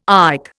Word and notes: IC: Indian

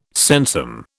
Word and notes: sensum: British English

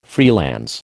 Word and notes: freelance: American English

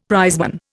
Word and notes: prizeman: Indian

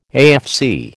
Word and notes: AFC: American English

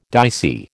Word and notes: dicey: American English

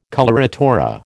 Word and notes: coloratura: American English

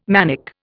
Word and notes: manic: Indian

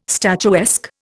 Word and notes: statuesque: Indian

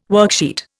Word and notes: worksheet: Indian

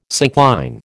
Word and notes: syncline: American English

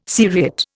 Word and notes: seriate: Indian

